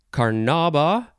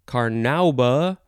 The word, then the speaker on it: carnauba: And then our last word is, you could pronounce this a few ways. It is Carnauba, Carnauba, Carnauba.